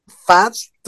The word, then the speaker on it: Pfaadt: Este que se apellida Fast, que se escribe, es Brandon Fast, se escribe PFAADT.